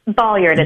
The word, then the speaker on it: Ballyard: Ballyard. It's like a ball in a yard.